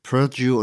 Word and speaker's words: Purdue: Wir reden in dieser Folge vor allem über ein ganz spezifisches Opioid aus dem Hause Purdue oder so spricht sich die Firma aus, ja. – Purdue und dann kommt Pharma.